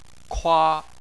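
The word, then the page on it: kua: tone 1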